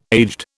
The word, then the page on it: aged: British English